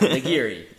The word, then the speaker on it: nigiri: Hey, I'm not I said it right the first time. Nigiri.